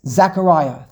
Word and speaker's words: Zechariah: How do you say Zechariah in English? – Zechariah. – Zechariah. You tell them this pasuk. – Zach is a very popular name these days. – Zechariah. You tell them this pasuk. You read these words and translate these words. You read them this pasuk, and you read what we just said over here in the name of the Ibn Ezra. – Zechariah. – Zachariah, thank you. Harav, Dr. Colonel Cohn, Shlomo Cohn has taught us the correct pronunciation.